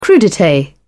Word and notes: crudites: British English

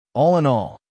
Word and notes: all in all: American English

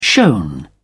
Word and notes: shown: British English

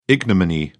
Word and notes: ignominy: British English

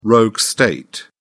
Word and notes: rogue state: British English

